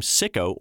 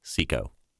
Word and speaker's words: Seiko: Seiko Paints once ran a TV ad campaign that made light of how people commonly mispronounced its brand name Sicko or Psycho. It's Seiko.